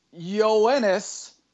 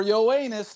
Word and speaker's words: Yoenis: And the winner is Yoenis, or Yoenis, depending on how you want to pronounce it, Cespedes.